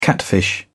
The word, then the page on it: catfish: British English